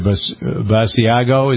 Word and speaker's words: Basiago: Times suggesting that Mr. Basiago, is that B-A-S-I-A? That's how you pronounce it, yeah. – Yeah, sounds like cheese.